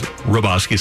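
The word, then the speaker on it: Hrabosky: It was Roboski, silent H.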